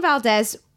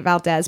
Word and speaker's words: Valdez: The Exxon Valdez is a boat, right? – It is a boat. – And it got the oil from the port, the Valdez. I keep calling it Valdez, but it's Valdez.